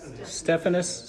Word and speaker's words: Stephanus: Am I saying that right? Is it Stephanus or Stephanus? Stephanus.